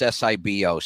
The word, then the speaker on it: SIBO: So let me explain what SIBO, SIBO, people pronounce it different. It's SIBO, small intestine bacterial overgrowth, like you said.